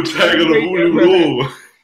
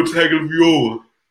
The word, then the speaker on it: Tagovailoa: Tua Tagovailoa. Like my man Rick Ross said, Tua Tagovailoa.